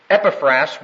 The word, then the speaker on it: Epaphras: There is, however, a very important man in this church, and his name is Epaphras, E-P-A-P-H. R-A-S, pronounced Epaphras.